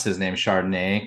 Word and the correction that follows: Charbonnet: because of course I like Chardonnay myself. But it is with a B. It's a Charbonnet. – Charbonnet.